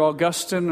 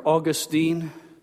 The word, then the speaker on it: Augustine: It was a man by the name of, it's pronounced either Augustine or Augustine.